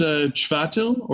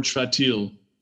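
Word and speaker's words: Chvátil: I believe it's Chvatil or Chvatil. – Okay. So it's a ch on the front.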